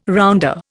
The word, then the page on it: rounder: Indian